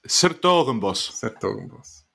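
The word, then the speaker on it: 's-Hertogenbosch: Sertogenbosch.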